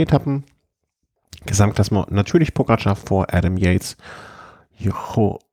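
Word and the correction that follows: João: Almeida, wie spricht man? Joao Almeida vom The Koenig Quickstep, Chris Harper, Nelson Paulus vom Team Education Nippo.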